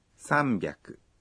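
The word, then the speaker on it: sambyaku (三百): Mais la façon de prononcer hyaku change après certains nombres. 300 se dit sambyaku.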